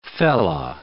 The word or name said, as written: fellah